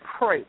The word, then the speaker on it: Kreutz: And it's Kreutz, like oi.